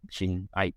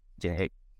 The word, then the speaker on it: Chin Aik: Chin Aik, as the A-I-K is pronounced as E-G-G. Chin Aik.